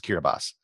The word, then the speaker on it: Kiribati: And one of them was the nation of Kiribati, which is spelled Kiribati, but it's pronounced Kiribati.